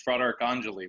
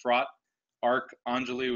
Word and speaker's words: Fratarcangeli: It's a long one. So it's Fratarcangeli. When you look at it in segments, it's Fratarcangeli, which is brother of the archangel.